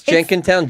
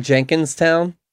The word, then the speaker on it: Jenkintown: Pronounce Jenkintown, Jenkinstown? – I Should I? – No, that was always like... – No, nobody does that.